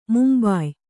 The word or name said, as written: ಮುಂಬಾಯ್